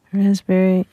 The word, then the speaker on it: raspberry: K-N-E-E-L, Neil. – Raspberry, answer. – Silent letters. – Yes, silent letters. Oh, yeah. Raspberry is... – The silent S... – P. – Or P. Sorry. – Silent P, right?